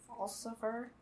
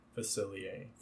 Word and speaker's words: Facilier: Dr. Falsifer? – Facilier.